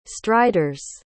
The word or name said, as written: striders